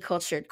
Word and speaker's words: Kultured: So once again, my name is The Cultured Queen. That's KULTURED.